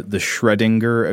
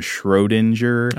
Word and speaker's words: Schrödinger: And eventually they came up with the theory of quantum physics, which is now predicted mainly by what is called – I've been saying his name wrong my whole life, I think. The Schrödinger equation. I've been calling him Schrodinger.